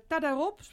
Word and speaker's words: Tadarop: Tadarop, zeg ik dat goed? – Ja.